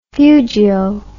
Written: fugio

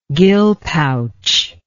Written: gill pouch

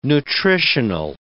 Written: nutritional

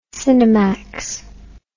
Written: cinemax

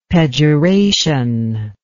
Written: pejoration

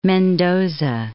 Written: Mendoza

